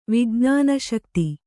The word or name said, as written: ವಿಜ್ಞಾನಶಕ್ತಿ